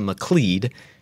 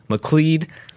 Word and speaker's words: McLeod: And, you know, just to help you remember that I pronounce it McLeod, just remember that it rhymes with lead. McLeod, lead.